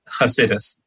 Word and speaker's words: Chassidus: Is a sought-out spiritual teacher in Jerusalem, a student of Torah, Kabbalah, and how do you pronounce that next one? Chassidus? – Chassidus. Very often just with the hey, yeah, with the H in the front, yeah.